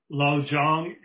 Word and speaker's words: Lojong: This is, we'll be studying a section of the Buddhist teachings that in Tibetan are referred to as Lojong. And that's one word. It's spelled L-O-J-O-N-G. Those short syllables tell you that it's a Tibetan word, Lojong.